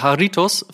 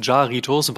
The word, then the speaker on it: Jarritos: Der Haritos, fälschlicherweise auch von uns, Jaritos, wahlweise auch Haridos ausgesprochen, sorry dafür,